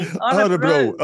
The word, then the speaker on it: Örebro: I still can't pronounce it properly. Can you say it again for me? – Örebro. Örebro, there you go.